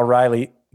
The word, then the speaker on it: Raleigh: No, that's actually what I was thinking too when I looked at the data, because Cal Raleigh, did I say that right? – Yeah. – It's like the capital of North Carolina.